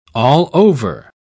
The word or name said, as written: allover